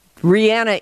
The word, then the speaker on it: Rihanna: The embarrassing thing is that Rihanna is how you say her name.